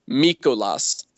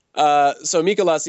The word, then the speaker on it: Mikolas: You know, the Pirates traded Dickerson and they received in return relief pitcher Miles Mikolas. I believe that is how his, I'm assuming, Grecian last name is pronounced. – I'm assuming that's how his countrymen would pronounce his name, yes.